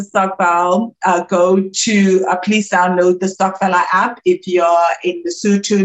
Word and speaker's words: Stokfella: Stockfella is spelled Stokfella.